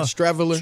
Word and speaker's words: Streveler: That guy, Chris Streveler, is that how you pronounce it? – Yeah, Streveler.